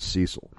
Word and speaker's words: Cecil: That's a rare but not too uncommon name here in America, and most Americans that I know pronounce it Cecil. – But anyway. Yeah, I appreciate that is the American pronunciation.